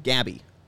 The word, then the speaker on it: Gaby: Yeah, like Gabby. Like if you had the name Abby, yeah. Added a G.